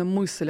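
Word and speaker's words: Myśl: А, действительно, мысль, я думал, как это читается. – Мысль, да.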